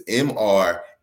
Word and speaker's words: MrJappi: So I grew up Latino, so it's MRJAPPI. So you wouldn't see the name Jose and say Jose, you put the H sound. So my happy is spelled with a J.